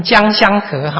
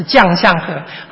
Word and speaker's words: 将相和: 好，那这样你就知道廉颇是谁了。你不要把将相和念成将相和哈，是将相和。